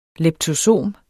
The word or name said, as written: leptosom